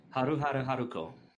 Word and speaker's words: Haruhara Haruko: It is pronounced Haruhara Haruko.